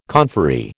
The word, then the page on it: conferee: American English